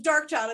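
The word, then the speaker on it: Darkchild: which was like a producer back then who always sounds like Dog Chow in Destiny's Child at the beginning, Say My Name, where it's like Dog Chow. Dark Chow. Sounds like Dog Chow, but it's Dark Child.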